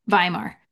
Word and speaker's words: Weimar: Yes, my sojourn in Berlin and Vienna during the Weimar era. Am I saying that correctly? – Weimar.